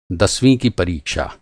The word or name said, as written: दसवाँ